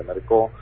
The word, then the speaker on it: Mercau: Presentamos en el Juzgado Federal de Jujuy una denuncia contra el juez provincial de Jujuy, Mercau, se escribe, se pronuncia Mercó,